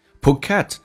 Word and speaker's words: Phuket: Phuket 指的是普吉岛，重音在后面 Phuket.